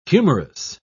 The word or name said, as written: humorous